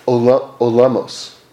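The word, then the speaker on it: olamos (עולמות): You see, we talk about olamos. That's how you say it in Hebrew, which means worlds. And in fact,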